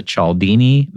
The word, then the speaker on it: Cialdini: Cialdini Method Certified Trainer designation.